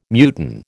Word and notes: muton: American English

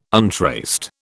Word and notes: untraced: British English

